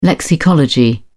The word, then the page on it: lexicology: British English